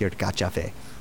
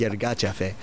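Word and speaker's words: Yirgacheffe: He's like, it's actually pronounced your gotcha fe. Like, that's how he said it too, like, you gotcha fe.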